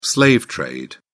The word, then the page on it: slave trade: British English